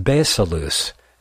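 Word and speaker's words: basileus (βασιλεύς): To avoid confusion here, we look to the Greek for the word kings, pronounced basileus, meaning a sovereignty or foundation of power, a kingdom.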